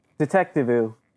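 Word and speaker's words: Detectivú: Detective. Detective. Rhymes with LeClue.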